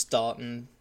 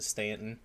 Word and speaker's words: Staunton: It looks like it's pronounced Staunton, but I think people pronounce it Stanton.